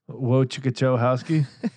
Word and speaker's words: Wojciechowski: Wojciechowski.